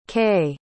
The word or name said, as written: kaye